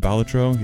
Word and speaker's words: Balatro: Balatro, apparently that's how you say it, Balatro, yeah, Kirby, and so much more.